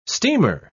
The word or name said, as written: steamer